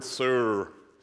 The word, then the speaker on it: sur: It's the Hebrew word sur. It's hard to, it's S U R in English. Uh, but it's kind of weird pronunciation. Maybe not. Sur, and, and it means bedrock.